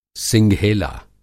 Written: सिंघेला

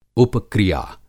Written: उपक्रिया

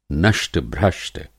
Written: नष्ट-भ्रष्ट

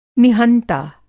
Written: निहंता

